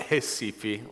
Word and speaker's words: Recife: We pronounce it Recife, like an H.